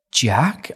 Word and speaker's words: Jaaq: I was like, listen, what if I say J-A-A-Q, just pronounce it, he's dyslexic. He went, Jack?